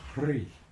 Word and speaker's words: Hrih: Now in Lama Govinda's teaching, the seed syllable for Amitabha is Hri, H-R-I-H.